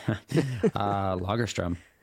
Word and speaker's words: Lagerström: And, Tell me about the etymology, if that's the right word, of your last name and how you would pronounce it in the mother tongue. – Lagerström.